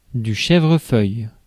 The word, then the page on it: chèvrefeuille: /ʃɛvʁəfœj/; /ʃɛ.vʁǝ.fœj/